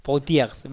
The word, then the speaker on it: Poitiers: Y en el año 732 consiguen ponerle fin al avance omeya en la batalla de Poitiers, no sé cómo se dice, ¿vale? Sí. Poitiers, vale, muy bien.